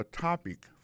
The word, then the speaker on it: topic: What's your topic for today? Alors, mot topic là qui signifie sujet, ça veut dire accent tombe là tombé sous premier syllabe.